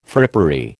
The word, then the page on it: frippery: American English